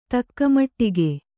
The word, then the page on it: ತಕ್ಕಮಟ್ಟಿಗೆ: Kannada